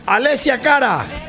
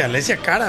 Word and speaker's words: Alessia Cara: Alessia Cara. – ¿Verdad? Sí, muy bien. – Dijiste Alessia Cara.